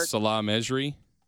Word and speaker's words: Salah Mejri: Salah Mejri.